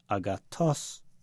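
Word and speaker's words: agathos (ἀγαθός): So you could, for example, say the word for good in Greek, agathos, but they said it agathos. You hear the voice going up on the last syllable.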